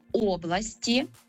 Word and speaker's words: області: Тому кажемо «області», родовий відмінок «областей», і далі повертаємося до наголосу на «о».